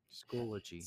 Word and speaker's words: Schoology: You don't rec Schoolology? – Schoology. – Schoology. I know. I keep saying it wrong.